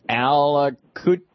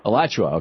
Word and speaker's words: Alachua: Alachua. I know I'm mispronouncing that. Florida? – Alachua. – Okay, there you go.